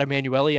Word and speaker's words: Emanuelli: You can find me online if you want to, at Todd Emanuele on Twitter, or just search for my name. My last name is spelled E-M-A-N-U-E-L-L-I.